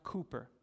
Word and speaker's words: Cowper: He spells his name, it looks like Cowper, C-O-W-P-E-R, but it's pronounced Cooper.